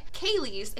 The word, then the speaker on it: Ceilidhs: I pronounced that correctly. I think I get a gold star for the day. Ceilidhs involve dances by varied formations of 2 to 16 people.